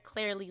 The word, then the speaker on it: Clairely: but you can find me at Clearly Legal, CLAIRELY.